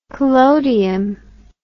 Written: collodium

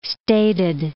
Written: stated

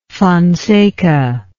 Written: Fonseca

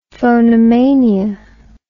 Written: phonomania

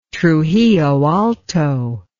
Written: trujilloalto

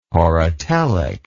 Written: horotelic

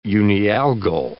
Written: unialgal

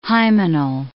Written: hymenal

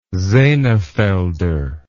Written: Senefelder

